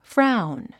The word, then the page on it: frown: fráun; フラァウン